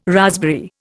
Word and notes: raspberry: Indian

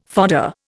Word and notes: fodder: Indian

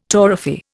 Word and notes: torrefy: Indian

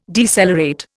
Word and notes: decelerate: Indian

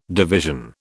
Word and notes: division: British English

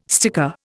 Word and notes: sticker: Indian

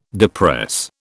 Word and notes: depress: British English

